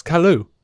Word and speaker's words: Kalu: What I think I adored more because it felt like it was an ad lib was Seg correcting Lobo on how you pronounce Kalu. – Oh, yeah. – He's like, Kalu, Kalu, Kalu, it's Kalu. Whatever.